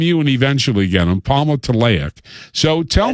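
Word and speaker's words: Palmitoleic: And how do you say that again? Palmitoic acid? – Palmitoic acid. Yeah. Okay. – Palmitoic. Yeah.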